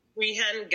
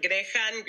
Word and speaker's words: Grehan: Grehan, Grehan, Grehan. It's Irish, so.